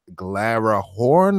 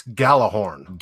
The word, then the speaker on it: Gjallarhorn: and now Good Morning Glarahorn. Am I saying that right? – No, it's Gjallarhorn.